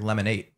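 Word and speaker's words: Lemon8: I think it's is. supposed to be pronounced like lemonade. – Oh, like lemonade. lemonade.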